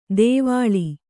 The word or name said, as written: ದೇವಳಿ